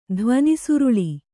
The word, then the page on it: ಧ್ವನಿಸುರುಳಿ: dhvani suraḷi